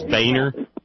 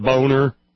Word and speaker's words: Boehner: Just like in Ohio, John Boehner is Boehner. – Right. – Not Boner.